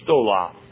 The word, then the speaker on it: stola: is the word stola. Stola. Now there are two words. One is stola and one is stole. S-T-O-L-A or S-T-O-L long A. Stola, short A, stole, long A.